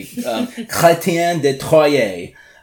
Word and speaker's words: Chrétien de Troyes: And so, also in the 12th century, we see, Count Dumoné, let me know if I'm pronouncing this incorrectly, Chrétien de Troyes. – The pronunciation is fantastic.